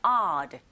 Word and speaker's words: odd: Because they sell 오두 하지 마시고 여러분. 아, 이번에 강세가 있기 때문에 철자 오에 강세가 있다. 아에 가깝게 발음해 주세요. odd.